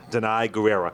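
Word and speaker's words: Danai Gurira: Denai Guerrera.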